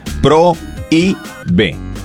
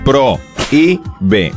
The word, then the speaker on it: prohíbe: Porque tiene un hiato. La O y la I se pronuncian en sílabas distintas. Pro, I, B. ¿Ok? – Uh-huh. – Pro, I, B.